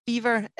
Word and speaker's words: Biewer: So, beaver, it's German. It's spelled B-I-E-W-E-R.